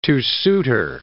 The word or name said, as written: two-suiter